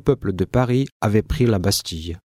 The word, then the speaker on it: la Bastille: Обратите внимание на произношение слова la Bastille.